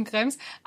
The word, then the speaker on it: Krems: Nein, du hast es ganz richtig ausgesprochen, Krems.